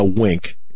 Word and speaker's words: Winc: Now, Wink, it's spelled W-I-N-C.